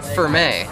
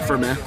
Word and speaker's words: Firme: So we have Firme. – Sounds right. – Is that how you pronounce it? – Seems right. – Fermi, not like Fermilab. Ferme. – I would say like Ferme. – Yeah, Ferme.